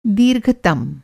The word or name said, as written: दीर्घतम